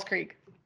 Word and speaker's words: Creek: And she was like, I hate when people call it crick because it's spelled C-R-E-E-K, which is creek. Creek.